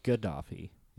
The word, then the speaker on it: Gaddafi: It is Gaddafi. Isn't yes.